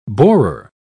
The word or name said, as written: borer